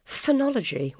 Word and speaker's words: phenology: I think the scientific word that you've used is phenology, which I'd never heard of. Is that pronounced correctly? – Yeah.